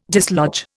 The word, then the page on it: dislodge: Indian